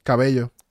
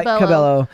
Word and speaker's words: Cabello: Cabello? – Cabello. – Camilla? Camilla? – Camila Cabello. Okay, Cabello, well, I don't know. – Cabello. – In Spanish, it would be pronounced Cabello.